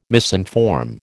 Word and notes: misinform: American English

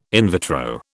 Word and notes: in vitro: British English

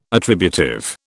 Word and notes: attributive: British English

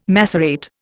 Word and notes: macerate: Indian

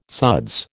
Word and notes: suds: American English

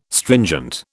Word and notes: stringent: British English

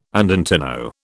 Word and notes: andantino: British English

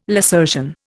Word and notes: lacertian: Indian

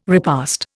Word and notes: repast: Indian

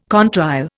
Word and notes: contrail: Indian

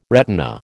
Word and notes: retina: American English

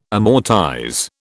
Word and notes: amortize: British English

